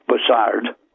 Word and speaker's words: Bussard: Yeah, I think it might be pronounced Bussard, but you can call it Buzzard if you like.